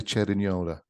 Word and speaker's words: Cerignola: One of my favorites is, I'm going to say this wrong, but Bella di Cerilogna. – The Bella di Cerignola, yes. – Cerignola.